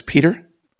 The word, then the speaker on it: Peter: Okay, it's my first name separated from my last name by a dot, so it's Peter, P-E-T-E-R, dot Hagen, and that's spelled H-A-G-E-N, at Stockton, Stockton.edu.